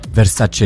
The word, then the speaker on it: Versace: It's like Versace. Versace is pronounced Versace.